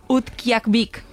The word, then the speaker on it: Utqiagvik: Utqiagvik.